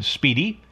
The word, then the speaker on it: SPDY: So you've probably already worked this out for yourself, but SPDY is pronounced speedy.